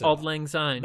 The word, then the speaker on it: Auld Lang Syne: Auld Lang Syne.